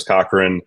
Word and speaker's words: Cochran: My name is Chris Cochran.